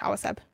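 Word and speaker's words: Awaceb: Awaseb. Awa, like water in Spanish, Awaseb.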